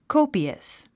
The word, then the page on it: copious: kóupiəs; コゥピアス